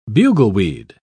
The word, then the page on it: bugleweed: British English